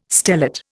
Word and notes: stellate: Indian

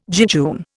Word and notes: jejune: Indian

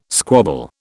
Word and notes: squabble: British English

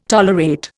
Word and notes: tolerate: Indian

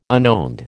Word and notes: unowned: American English

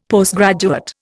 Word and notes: Postgraduate: Indian

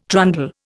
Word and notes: trundle: Indian

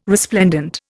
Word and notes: resplendent: Indian

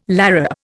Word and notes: larrup: Indian

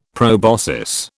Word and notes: proboscis: British English

